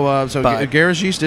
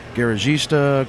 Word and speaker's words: Garagiste: So Garagiste is the correct pronunciation. We've heard Garagista, Garagist, Gargle-geist.